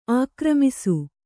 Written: ಆಘೂರ್ಮಿಸು

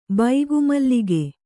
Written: ಬೈಗುಮಲ್ಲಿಗೆ

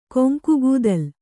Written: ಕೊಂಕುಗೂದಲ್

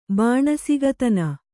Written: ಬಾಣಸಿಗತನ

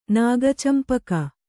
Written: ನಾಗಚಂಪಕ

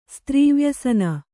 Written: ಸ್ತ್ರೀವ್ಯಸನ

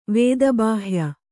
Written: ವೇದಬಾಹ್ಯ

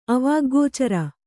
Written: ಅವಾಗ್ಗೋಚರ

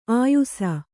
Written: ಆಯುಸ

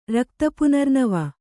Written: ರಕ್ತಪುನರ್ನವ

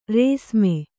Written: ರೇಸ್ಮೆ

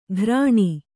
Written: ಘೃಣಿ

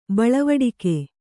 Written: ಬಳವಡಿಕೆ

